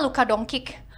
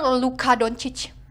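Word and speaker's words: Luka Dončić: ya mungkin aja dia pelafalannya Amerika Inggris gitu kan ya udah gue bilang lah Luka Doncic dan ternyata itu salah dan literally yang benar Luka Doncic Doncic sesuai dengan tulisannya.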